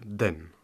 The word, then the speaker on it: 等: Alors, Xiao Tai, attendre se prononce donc den en chinois.